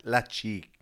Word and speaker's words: Lecce: So, how do you say it again? – Lecce. – Lecce. – Yeah.